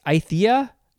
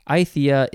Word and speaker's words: Aythya: The scientific name is, how do you pronounce this? Aythia? Aythia vallisneria. Aythia is spelled A-Y-T-H-Y-A. Aythia.